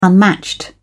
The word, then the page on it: unmatched: British English